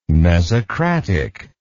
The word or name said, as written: mesocratic